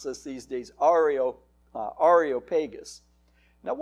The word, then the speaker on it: Areopagus: And the correct pronunciation, and I'll qualify that in a minute, is Areopagus. I was reading A-E-R-O, which would be Areo, Areo, but it's not. It's Areopagus.